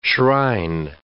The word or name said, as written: shrine